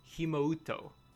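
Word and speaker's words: Himouto: My first question is it pronounced Himoto or Himoto? – Himoto, the U is silent.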